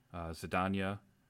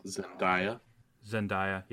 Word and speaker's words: Zendaya: Zendaya, are all in it. – Zendaya, yeah, sorry.